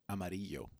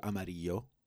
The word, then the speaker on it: Amarillo: Just Amarillo, Texas. It's Amarillo. – Oh. – That's yellow. – Is that how you'd pronounce it? – No, but if you say it that way, people think you're weird.